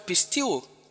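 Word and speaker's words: Pisteuo (πιστεύω): That's the Greek word pisteuo. Pisteuo. Pisteuo is spelled P-I-S-T-E-U-O.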